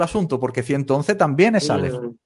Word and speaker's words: Aleph: Pero cuando yo veo el nombre de la letra Aleph, que se dice así, Aleph,